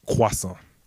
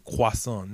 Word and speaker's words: croissant: Croissant. – Croissant. – You got to say croissant, not croissant.